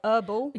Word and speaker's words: herbal: Herbal. – Yeah, we don't pronounce the herbal. H here.